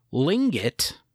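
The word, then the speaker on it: Tlingit: And again, Lingit is not pronounced with the T sound. There's a T in the front of the word.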